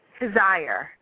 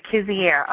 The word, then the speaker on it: Kizier: It's pronounced Kizier. It's like desire. – Heath Kizier. What did I say? Kizier.